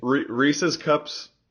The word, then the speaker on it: Reese's: pronunciation, because it's Reese apostrophe S. They're his cups. It's Reese's cups.